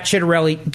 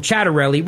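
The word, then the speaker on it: Ciattarelli: So Scott Pressler, who doesn't work here, doesn't have a show, goes on Fox News and goes on about Jack Chitterelli. Can't even pronounce his name right, by the way, but that's neither here nor there. Calls him Chatterelli, which is fine.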